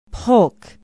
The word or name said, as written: polk